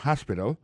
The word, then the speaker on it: hospital: L'hôpital, c'est comme ça, on dit ça en anglais, hospital. Alors, accent tombe sur le premier syllabe, là, il y a trois syllabes, puis she likes to visit hospital. Hospital l'a écrit H-O-S-P-I-T-A-L, hospital, l'hôpital. Par exemple, like là, ça veut dire son bagage ou remet fait.